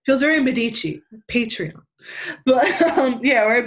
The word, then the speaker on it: Patreon: And I also have, I can never pronounce this fucking word, a Patreon? Patreon? – Patreon. – Patreon. I got one of those Johns. – Patreon. Say it with me.